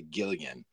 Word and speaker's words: gillion: And, and particularly when you realize that a, a, a gillion, or some say a gillion, I call it a gillion. Yeah. A gillion is enough because it's spelled with a G, and I think that's a hard G.